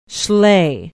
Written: Schley

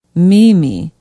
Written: meeme

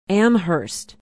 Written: amherst

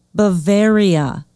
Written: bavaria

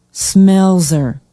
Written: smelser

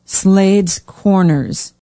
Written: Slades Corners

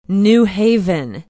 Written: new haven

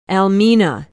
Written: almena

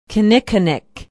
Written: kinnickinnic

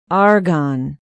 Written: argonne

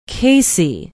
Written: casey

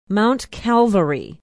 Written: mount calvary

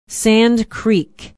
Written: Sand Creek

